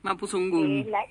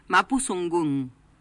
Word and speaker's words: Mapuzungún: el Mapuzungún, creo que es, no me acuerdo, no lo pronuncio bien. – Mapuzungún. – ¿Cómo? – Mapuzungún. – Mapuzungún. – Ahí está.